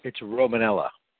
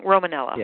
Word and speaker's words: Romanella: Yeah, and it's Romanella. – Did I say that wrong? Romanella.